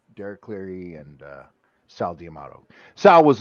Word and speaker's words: Cleary: Oh God, the judges, Derek Cleary, or however you say his name. Man, Cleary, you got it right.